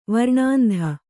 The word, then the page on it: ವರ್ಣಾಂಧೆ: varṇāndhe